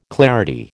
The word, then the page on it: clarity: American English